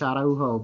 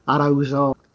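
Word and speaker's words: Araújo: Yeah, I actually listened to a few different talk show interviews to get the pronunciation right, because otherwise I would have gone with the Spanish Araujo, but it is more Portuguese, and so it sounds more like Araujo.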